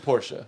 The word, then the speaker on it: Porsche: I'll interchange it sometimes, but it's Porsche.